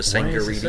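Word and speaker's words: Tsangarides: Sangaridis. – Ouais, j'ai jamais su le prononcer comme faux, finalement. – Je pense que tu prononces pas le T, Sangaridis.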